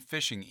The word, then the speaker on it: phishing: P-H-I-S-H-I-N-G, pronounced phishing,